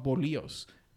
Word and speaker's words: bolillo: In Mexico, they're called bolillos.